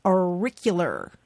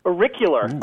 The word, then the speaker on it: auricular: How about another name for your pinky is your auricular? – Auricular. Oh, that's good. – Auricular. Let me spell that for you. A-U-R-I-C-U-L-A-R. Auricular.